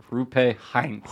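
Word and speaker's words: Roope Hintz: Rupe Hintz.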